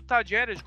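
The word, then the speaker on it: Talleres: Mas o Tajeres, como se fala na Argentina,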